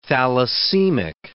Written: thalassemic

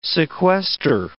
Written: sequester

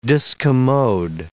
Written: discommode

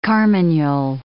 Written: carmagnole

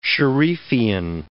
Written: sharifian

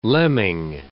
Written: lemming

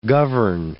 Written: govern

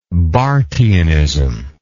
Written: barthianism

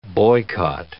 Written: boycott

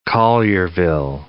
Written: Collierville

